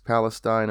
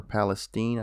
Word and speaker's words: Palestine: I don't know if anyone out there has heard about the train derailment in East Palestine, Ohio, or Palestine, I think is how they pronounce it down over there.